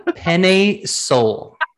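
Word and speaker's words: Penei Sewell: let me pronounce it for you. Penne Sewell.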